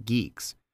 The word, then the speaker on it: GUIX: One such effort is GUIX, pronounced Geeks, which uses functionally declared package management, leading to bit-for-bit reproducible builds by design.